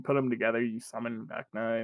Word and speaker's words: Vecna: Be Wild, Vecna from Stranger Things. Did I say that right? – Yeah, Vecna.